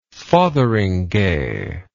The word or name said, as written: fotheringhay